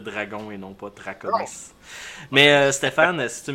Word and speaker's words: Draconis: C'est Draconis. – Draconis, excuse-moi. C'est à cause que moi, actuellement, j'ai ton image Hangout, qui est un blason avec un dragon. Fait que ça m'a influencé dans la prononciation.